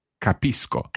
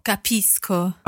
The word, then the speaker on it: capisco: So if C goes straight into O, then it's a hard C sound. – Aha, co. – Capisco. – Capisco.